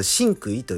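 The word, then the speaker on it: 心口意: この 3 文字を続けて聞いて、これ、真句意と読むんですけど、これ、すべての武道の源、そしてね、すべての、えー、医療とかの、ん医療じゃないな、宗教か。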